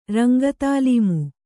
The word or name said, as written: ರಂಗತಾಲೀಮು